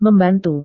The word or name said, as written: membantu